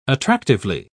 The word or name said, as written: attractively